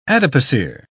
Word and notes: adipocere: British English

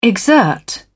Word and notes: exert: British English

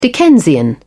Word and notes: dickensian: British English

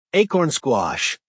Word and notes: acorn squash: American English

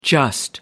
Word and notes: just: American English

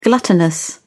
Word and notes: gluttonous: British English

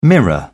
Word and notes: mirror: British English

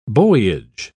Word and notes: buoyage: British English

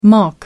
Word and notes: mach: American English

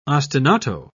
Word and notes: ostinato: British English

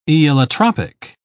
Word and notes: aeolotropic: British English

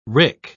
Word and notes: wrick: British English

